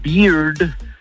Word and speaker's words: beard: Porque con, o sea, la barba se pronuncia beard, el oso se pronuncia bear, y la cerveza se pronuncia beer. Pero con R al final.